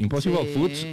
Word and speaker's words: Impossible Foods: Impossible Foods, ¿lo dije bien, por favor? – Sí, sí, ¿no? Perfecto. – Muy bien, Impossible Foods, que tenía una valoración, y es una marca de comida saludable, que tenía una valoración de $200 millones de inicio.